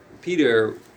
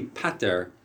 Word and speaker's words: pater: So there's a historian that has a theory that this Rabbeinu Peter we're pronouncing wrong. It's really pater, which is father in Latin.